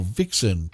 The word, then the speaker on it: vixen: V is for vixen, pronounced with a W.